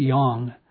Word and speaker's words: yang: So one, so yang, and I'm mispronouncing yang. I should try to do a better job on that. But it's more pronounced as yang, Y-A-N-G.